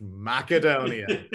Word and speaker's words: Macedonia: You're smarter than all of us, and it's pronounced Macedonia.